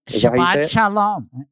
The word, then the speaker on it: Shabbat Shalom: Shabbat Shalom. – Shabbat Shalom.